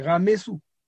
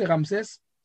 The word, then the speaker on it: Ramsès: Donc, euh, euh, par exemple, on a des pharaons comme Ramsès, c'est Ramessou. Ça veut dire que le soleil l'a enfanté. Il a été enfanté par le soleil. Ramessou. C'est prononcé Ramsès, un peu à la grecque, mais c'est Ramessou.